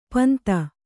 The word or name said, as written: ಪಿಂದು